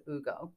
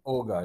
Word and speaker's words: Hugo: And really the local one is called, it's spelt Hugo, but they pronounce it Ugo. – Ugo, yeah.